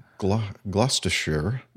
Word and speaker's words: Gloucestershire: Chemists at a lab in Gloucestershire. Mm-hmm. Did I say that right? – Yeah, pretty good.